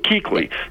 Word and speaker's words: Kuechly: Now, what they did do, though, was they spell his name in a way to confuse basketball fans, because it is pronounced Kuechly, but that's not your fault. I didn't know that. – Because that's a ridiculous way to pronounce that name, I'm just saying.